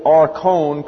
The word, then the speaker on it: archon: A-R-C-H-O-N, pronounced archon.